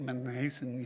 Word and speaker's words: Mnason: Mnason, that's how you say his name, it's M-N-A-S-O-N, but you can't say it Mnason, you know, I guess you could, but the proper way of saying it is Mnason, an old disciple with whom we should lodge.